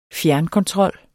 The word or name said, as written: fjernkontrol